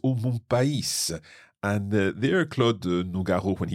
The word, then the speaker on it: pays: and therefore we pronounce the S on pays, and if you're reading the text, you'll also notice that it's got a circumflex on the I.